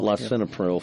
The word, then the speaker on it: Lisinopril: Jennifer wants to know, I struggle with slightly elevated blood pressure and have taken 5 milligrams of Lisinopril, was that right? I didn't pronounce it. Lisinopril. – Lisinopril, yeah.